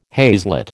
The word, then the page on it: haslet: American English